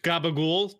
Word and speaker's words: capicola: Capicola. – I have no idea what any of this is. – We're talking about capicola. Which is pronounced gabagool to the North Jersey and Brooklyn Italians.